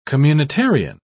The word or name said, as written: communitarian